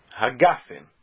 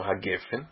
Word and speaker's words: hagafen: Gefen é como se fala quando está no meio da frase. Mas quando é a última palavra da frase, o segol é transformado em kamatz. Então, por isso, bore peri hagafen, porque é fim da frase.